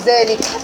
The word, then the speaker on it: ذلك: في طبعا في ذلك ناخد بالنا من ذلك الالف بتنطق ولا تكتب. ذلك احنا بنكتب كده، ذلك بنكتب ذلك كده، وانا بكتب في المصحف بيحط لك الف صغيره كده. يبقى عندي في الكتابه بتاعتي بقول ذلك، بيبقى فيها مد بالالف ينطق ولا يكتب، اللي هي الالف الصغيره دي.